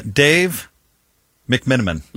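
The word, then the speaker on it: McMenamin: Dave McMiniman. – Mm-hmm. – Did I say that right? – Yes.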